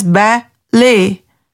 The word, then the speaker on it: ballet: Like in ballet, it is pronounced as ba-lay.